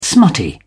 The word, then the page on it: smutty: British English